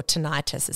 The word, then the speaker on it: tinnitus: And I think it's around like 14% of the population that have tinnitus, okay? Or tinnitus, as some people pronounce it.